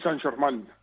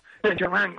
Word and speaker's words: Saint-Germain: París Saint-Germain. – Saint-Germain.